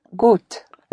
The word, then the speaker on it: gut: The gut is spelled G-U-T. The U always is pronounced like a double O in English, like in food.